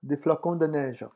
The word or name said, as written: des flocons